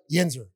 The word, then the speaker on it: Yinzer: Yinzer, did I say that right? – Yinzer.